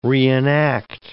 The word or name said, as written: reenact